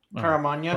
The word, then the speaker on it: Caramagna: Did I say it wrong? – I think the G comes before the N. – Caramagna? – Caramaga? – Yeah, Caramagna, maybe, because Italian...